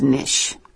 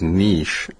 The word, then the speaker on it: niche: First of all, what's a foods niche? – I actually pronounce the word differently. I pronounce it as niche. I suppose both are okay. So if I say niche, please don't write in and complain.